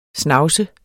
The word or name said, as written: snavset